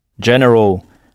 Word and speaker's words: general: Et si vous veillez bien à prononcer le « g » comme un « j », vous n'aurez donc aucun mal à traduire le mot « général » en anglais.